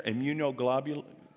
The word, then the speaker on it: immunoglobulin: immunoglobulin. Did I say that right, doctors in here? Immunoglobulin? Okay, thank you.